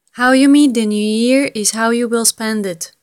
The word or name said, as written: how you meet the new year